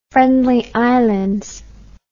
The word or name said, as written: Friendly Islands